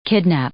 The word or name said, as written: kidnap